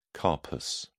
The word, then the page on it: carpus: British English